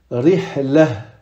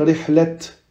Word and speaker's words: rihla: mais si on va s'arrêter sur ce mot-là, on va le prononcer comme un hai. Et on va dire rihla. Rihla. On va pas dire rihlet.